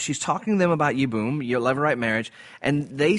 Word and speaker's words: Yibum: Yibum, Y-I-B-U-M.